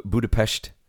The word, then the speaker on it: Budapest: You've been to Budapest. – That's right. Got engaged there. – That is how it's pronounced. – That is the correct pronunciation.